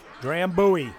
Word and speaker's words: Drambuie: Drambuie.